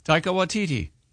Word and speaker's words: Taika Waititi: Taika Waititi. – Okay, I thought it was like take a titty.